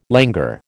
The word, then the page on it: languor: American English